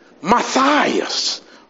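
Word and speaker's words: Matías: His name was Matthias. Oh, that's a difficult name to pronounce for me. In Spanish, it's Matias. When I say 1, 2, 3, you say it in Spanish, Matias. 1, 2, 3.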